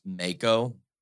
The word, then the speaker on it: Mako: Princess, I think her name is pronounced Mako. It's M-A-K-O.